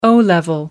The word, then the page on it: o level: American English